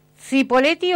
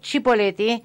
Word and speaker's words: Cipolletti: ¿Se pronuncia Cipolletti o Cipolletti? – Como quieras. – Que tengan buen fin de. – Como quieras. – Las dos están aceptadas.